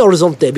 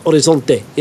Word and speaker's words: Horizonte: I say Horizonte because it's Brazilian. I'm not pronouncing the H on purpose. Horizonte, yeah? – Oh, well done.